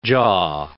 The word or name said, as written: jaw